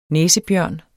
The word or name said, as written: næsebjørn